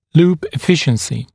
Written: loop efficiency

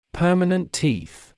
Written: permanent teeth

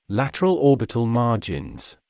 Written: lateral orbital margins

